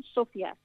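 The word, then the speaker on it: Sofia: Sí, mira, aterrizamos en Sofía, que por cierto ellos pronuncian Sofía, es claro.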